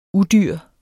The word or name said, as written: udyr